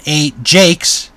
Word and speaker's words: Ajax: So, this was called at one point the Ajax, as in a Jakes, then a slang word for toilet. So it's actually Ajax, not Ajax. It's spelled Ajax,